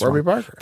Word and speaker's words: Warby Parker: Correct me. I'm saying it wrong. I'll say this. – Warby Parker. – There we go. Warby Parker.